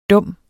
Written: dum

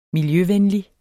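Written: MILJØVENLIG

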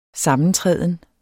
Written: sammentræden

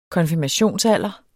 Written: konfirmationsalder

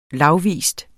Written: lagvis

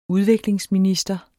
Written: udviklingsminister

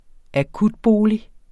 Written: akutbolig

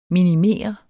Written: minimere

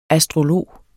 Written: astrolog

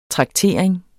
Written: traktering

